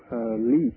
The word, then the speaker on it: Leeq: And it is by a music project that I called Leek, which is spelled L-E-E-Q.